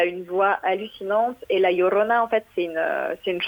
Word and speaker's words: La Llorona: Est-ce que la Lorana, est-ce que je le prononce bien C'est qu'est-ce que ça veut dire la Llorona.